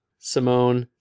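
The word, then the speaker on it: Simón: S-I-G-H in all caps, and then M-U-H-N in lowercase. So Simon, okay? Mm-hmm. It's yeah. Also got his name, though, which is S-I-M-O-N with an accent mark over O. See, Spanish, yes. Spanish, it has a pronunciation guide. It's yes. – A perfectly phonetic language that has accent marks over the syllable that is to be stressed.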